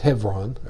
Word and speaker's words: Hebron: So Hebron, if you study Hebrew history, Hebron, they call it Hebron, is the way they pronounce it.